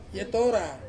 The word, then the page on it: etora: Ansus